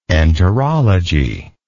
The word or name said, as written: enterology